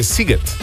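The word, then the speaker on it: Sziget: Tukaj nas je nekdo od vas poučil, da se SZ v mađarščini izgovarja kot S, torej Siget.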